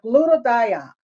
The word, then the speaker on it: glutathione: Okay, the glutathione. Am I saying that right? All right. – Yes, you are.